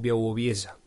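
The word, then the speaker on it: Białowieża: y se llama Bialobieza, con W, es como se escribe, pero se pronuncia algo como Białobieza, o algo así.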